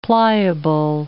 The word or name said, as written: pliable